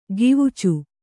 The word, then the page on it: ಕವಿಚು: kavicu